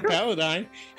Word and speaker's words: Paladine: He said Paladine. He actually said Paladine, right? – Yeah, he didn't say Paladine, which is one that I hear all the time.